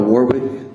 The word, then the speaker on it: Warwick: David Dale Warwick. – Mr. Warwick, can you spell that name for the record? Spell it. – W-A-R-W-I-C-K.